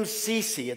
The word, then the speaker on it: Cecy: My name is Jim Cecy. It's kind of a strange name. It's spelled C-E-C-Y, but that's not really my name.